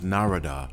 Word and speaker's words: Narada: I met him one time, and I think I called him Narada. He said, no, it's Narada, like Florida.